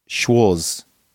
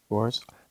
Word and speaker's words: Schwarz: Oh yeah, so Chris Schwartz, I can't try to pronounce it. Ends in a Z. Is that Schwartz or Schwarz? – Schwarz. – Schwarz. – Maybe it's my bad for saying Schwartz.